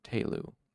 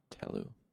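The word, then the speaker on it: Tehlu: Okay, so it's spelled T-E-H-L-I-N, so I was pronouncing it Tellin, but apparently it is Talin, and so it is also Talu, not Telu. Talu.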